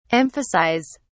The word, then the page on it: emphasise: British English